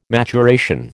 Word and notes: maturation: American English

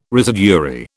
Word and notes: residuary: British English

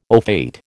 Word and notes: au fait: American English

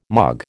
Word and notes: mog: American English

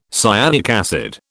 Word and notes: cyanic acid: British English

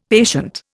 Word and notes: patient: Indian

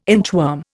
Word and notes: inchworm: Indian

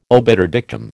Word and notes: obiter dictum: American English

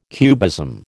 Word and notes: cubism: American English

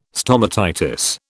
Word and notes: stomatitis: British English

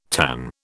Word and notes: tanh: British English

